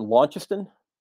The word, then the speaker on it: Launceston: And I'm gonna try to get this pronunciation correctly, and I always get this one wrong. But is it Launceston? I believe is how it's pronounced in Tasmania.